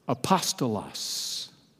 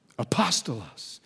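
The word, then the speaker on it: Apostolos (ἀπόστολος): Here's how you pronounce that word that the English renders as messenger. Apostolos. Apostolos.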